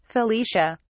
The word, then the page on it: felicia: British English